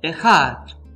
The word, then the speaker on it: ejad: Sin embargo, la palabra en voz hebrea que es utilizado en Deuteronomio 6.4 es el término ejat, en nuestra pronunciación castellana E, J, A, D.